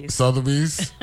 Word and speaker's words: Sotheby's: Sotheby's.